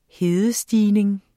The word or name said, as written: hedestigning